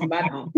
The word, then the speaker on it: Badon: So spelled like Erica Badu, it's just Badon.